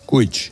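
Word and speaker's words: Kuić: Me has dicho que se pronunciaba Gordana Kuch. – Kuch.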